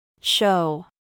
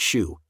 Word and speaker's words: shew: Spelled S-H-E-W. – An archaic spelling of the word show, spelled S-H-O-W, including its noun and verb forms of showed, shown, and showing, which is never pronounced shoe, even in combination with showbread.